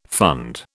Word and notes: fund: British English

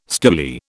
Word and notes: stoolie: British English

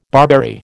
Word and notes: barberry: American English

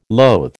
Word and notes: loathe: American English

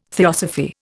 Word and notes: theosophy: Indian

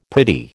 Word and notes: pretty: American English

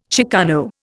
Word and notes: chicano: Indian

